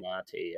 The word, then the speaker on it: Malnati: That Malnati, am I saying that right? – Malnati, yeah.